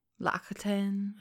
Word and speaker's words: L'Occitane: But my favorite that I will not be able to pronounce correctly, but it's L'Occitane. L'Occitane.